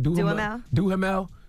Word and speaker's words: Duhamel: Duhamel.